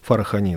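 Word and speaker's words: Фарахани: Фархани, да. Ударение на последний слог всегда, это специалисты сказали.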